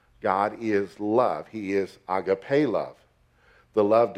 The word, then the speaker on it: agape: The two words primarily used and used in this conversation is the word phileia and the word agape, or agape is the correct pronunciation.